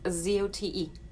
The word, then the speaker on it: Zote: Z O T E.